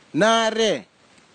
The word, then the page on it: nare: Kwamera